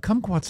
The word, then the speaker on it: kumquat: It is spelled K-U-M quad, right? – Yeah. – Kumquat? – Yeah. Yeah. Yeah. Kumquat sounds, it's like the country of Guam.